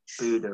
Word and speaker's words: Bodø: So you're currently in Bodø, is that right, the place? – Yeah, Bodø-Glimt, that's how you pronounce it. I've been doing Norwegian lessons, so yeah, Bodø is how you pronounce it. – Bodø, right, okay.